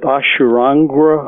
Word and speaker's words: ashwagandha: And one of the things that she had mentioned was something called ashwagandha.